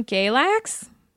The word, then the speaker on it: Galax: So Mary is from Galax. That's how you pronounce it, right? – Mm-hmm.